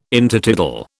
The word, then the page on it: Intertidal: British English